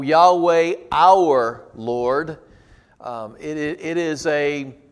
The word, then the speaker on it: Yahweh: It is most oftentimes pronounced Yahweh. So